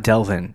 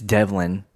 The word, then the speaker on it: Devlin: I said his name wrong on the last podcast, by the way. It's not Delvin. It's Devlin. Devlin Hodges.